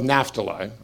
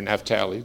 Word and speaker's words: Naphtali: Okay, so He was from the tribe of Naphtali, or Naphtali, depending on how you want to pronounce it, which means struggling or my wrestling.